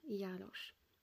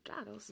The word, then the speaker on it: Jarosz: Bohaterka tego odcinka, Beverly Ann Jarosz, albo Jarosz, bo tak wymawia się to w Stanach Zjednoczonych, była 16-letnią dziewczyną, która dopiero zaczęła uczęszczać do szkoły średniej, czyli high school.